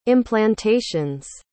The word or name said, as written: implantations